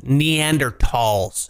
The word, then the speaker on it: Neanderthals: All like I'm saying is humans, as in Homo sapiens, he used to go to war with Neanderthals, is how you're supposed to pronounce it.